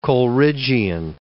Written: Coleridgian

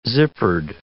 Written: zippered

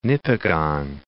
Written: Nipigon, Lake

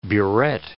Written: burette

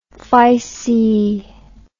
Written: phyceae

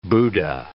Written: Buddha

